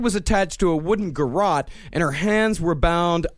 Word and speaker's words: garrote: How is this uh, pronounced? Garrote. It's garrote. A garrote, yes, because God forbid I mispronounce something.